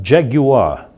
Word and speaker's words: Jaguar: Pois é, esse é o famoso Jaguar, como os ingleses gostam de pronunciar, Jaguar, ou simplesmente Jag, que é o apelido carinhoso que os britânicos dão ao produto nacional deles.